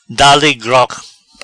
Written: Dale grog

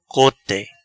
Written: Cote

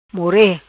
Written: More

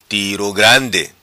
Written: Tiro Grandi

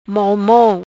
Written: Mol Mol

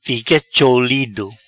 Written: Fica cholido

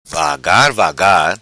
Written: vagar vagar

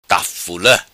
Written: Tafula

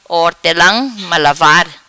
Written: Hortela Malavar

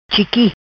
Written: Chiqui

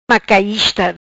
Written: Macaista

